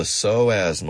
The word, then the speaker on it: psoas: The psoas muscle. It's Greek, so it's spelled P-S-O-A-S.